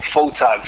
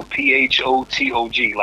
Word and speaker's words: Photog: Oh, Photog. So Photog, like photographer.